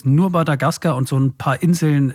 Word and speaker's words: Madagaskar: Betonung auf der letzten Silbe. Madagaskar.